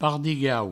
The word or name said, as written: pardigau